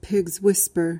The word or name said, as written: pigs whisper